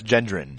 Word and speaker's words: Gendron: Rachel Gentleman. – Gentleman. – I can see how you'd be confused. No, it's Gendron.